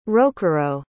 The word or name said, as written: rokurou